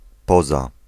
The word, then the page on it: except: British English; American English; /ɛˈksɛpt/; /ɪˈksɛpt/